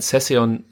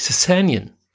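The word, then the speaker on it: Sessegnon: Ich war mir immer sehr sicher, dass man äh, Ryan Session, Session ausspricht, bis ich dann Sky geschaut habe. Ja. Und der Kommentator ganz inbrünstig davon gesprochen hat, dass die, ähm, dass viele Fans den Nachnamen von Session falsch aussprechen und es das heißt eigentlich Sessanian.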